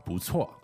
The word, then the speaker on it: 不错: 不错. – Okay, 不错. – And those are two fourth tones. – Okay, two fourth tones. And it's pronounced 不错.